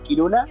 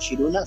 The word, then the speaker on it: Kiruna: Sí, ciudad cerca de Kiruna o Shiruna, según cómo se pronuncia ahí, según el dialecto de cada uno. Algunos dicen muy marcado Kiruna y otros dicen Shiruna.